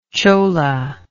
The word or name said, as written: chola